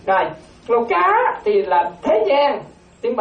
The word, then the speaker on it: Loka: Thì đó, chúng ta còn ở trong ba cái cõi giới này, mà tiếng Pali nó gọi là Loka, nó gọi là Loka. Loka, quý tử đọc ra đó, nghe đọc là Loka, mà quý tử đọc không được đó, quý tử nó một Loka.